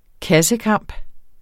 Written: kassekamp

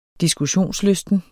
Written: diskussionslysten

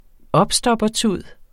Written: opstoppertud

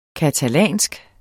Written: catalansk

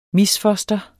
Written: misfoster

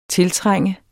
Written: tiltrænge

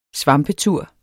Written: svampetur

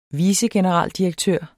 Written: vicegeneraldirektør

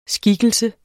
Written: skikkelse

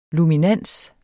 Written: luminans

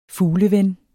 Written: fugleven

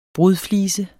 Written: brudflise

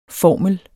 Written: formel

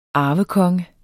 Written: arvekonge

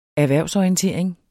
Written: erhvervsorientering

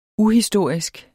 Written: uhistorisk

